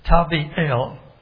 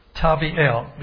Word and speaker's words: Tavi'el: But the way it normally would be pronounced, the name of this imposter is Tavi'el. Tavi'el, meaning God is good.